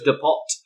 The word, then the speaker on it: depot: Why do you say depot is so weird? – It says depot. I don't know. – Judging by Kai's look, she's been too polite to say the same thing. – It's pronounced depot.